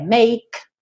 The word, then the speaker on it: make: Você percebe que no game já não vai pronunciar a letra E. Make, não pronuncia a letra E. Para no k. Som do K, make.